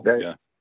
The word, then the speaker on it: Bergdahl: They said, You sold all these top Taliban leaders to this traitor, Bergdahl. Isn't that how you pronounce it? – Bergdahl.